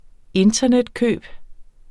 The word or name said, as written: internetkøb